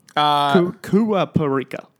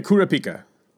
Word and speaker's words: Kurapika: I can never say his name. How do you say it? Kurapika. – Let me look it up. Yeah, Kurapika.